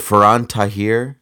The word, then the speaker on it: Faran Tahir: Is it Faran Tahir? Is that's, that how you say his name? Yeah, that's how I've heard it pronounced.